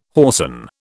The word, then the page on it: whoreson: British English